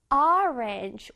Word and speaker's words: orange: orange. 오가 아니라 어 발음을 내줘야 돼.